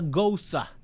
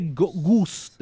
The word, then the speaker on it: Gose: esa es la Margarita Goza es un refreshing tart. Dice goose, eh, como goce, pero es goza, es que se pronuncia según me, me mencionaron la otra vez, que está Alicia.